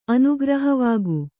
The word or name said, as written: ಅನುಗ್ರಹವಾಗು